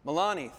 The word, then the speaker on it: Melania: It says, President Trump and First Lady, how do you say the First Lady's name? Melania? Melania, thank you.